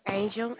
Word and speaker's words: Angel: Angel, A-N-G-E-L.